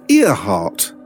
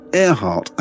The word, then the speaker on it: Earhart: He says, surely her name should be pronounced Earhart, not Earhart.